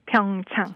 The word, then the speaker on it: Pyeongchang: I heard the Special Olympics World Games took place in uh, I don't know how to pronounce it えっとね、えー、It must be Pyeongchang. ピョンチャン Pyeongchang. – Pyeongchang, South Korea from January 29th until yesterday.